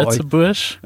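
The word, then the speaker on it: Lëtzebuerg: Äh, das Spiel, in, aus dem Du kommst, ist so ein bisschen kleiner vom Spielbrett und heißt äh, äh Litzeburg oder wie nennt man, spricht man ja, das bei Litzebusch.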